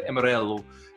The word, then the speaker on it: Amarello: And to go back, my name is actually pronounced Walter Amarello, and it's Portuguese.